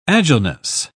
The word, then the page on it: agileness: British English